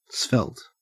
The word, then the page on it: svelte: Australian English